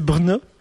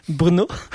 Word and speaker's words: Brno: Alors, la capitale de ce pays, c'est Brno. Bruno. – Brno.